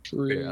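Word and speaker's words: Schröder: Am I butchering that? – Schroeder. – Which one is it again? – Schroeder. – Okay, I had it right.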